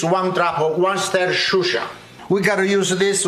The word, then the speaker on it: Worcestershire: Okay, I researched it. Worcestershire.